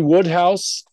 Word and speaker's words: Wodehouse: PG Woodhouse, I highly recommend. It's spelled Wode House, Wodehouse, but it's Woodhouse.